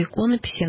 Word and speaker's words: иконописи: Матушка, как вы правильно сделали ударение иконописи, все-таки это правильнее. Икона же, правда, а не икона, как говорят, иконопись. Ну, это, наверное, такое профессиональное ударение иконописи.